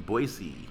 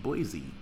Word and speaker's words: Boise: June 8th, the Olympic in Boise. And I learned from being there that it's not pronounced Boise. It's Boise.